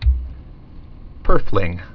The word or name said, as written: purfling